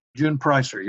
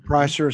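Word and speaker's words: Preisser: Did you say her name Pricer? – June Pricer, yeah. – Pricer is how you say that name.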